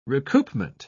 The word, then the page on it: recoupment: British English